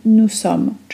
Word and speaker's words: nous sommes: która brzmi nous sommes, czyli my jesteśmy. Błąd polega na tym, że czasem wkrada się tam dźwięk z, który totalnie nie ma tam racji bytu. Wymawiamy to nous sommes z s,